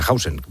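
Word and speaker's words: Huijsen: que digo Hausen porque ha vuelto a decir hoy en la tele él que se pronuncia Hausen.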